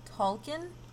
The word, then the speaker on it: Tolkien: Tolkien? – Yeah.